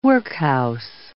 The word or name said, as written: workhouse